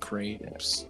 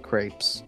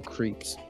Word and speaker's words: crepes: So I saw these things called, I pronounced them creeps, you know, and I was like, that's wrong.